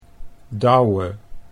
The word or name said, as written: Douwe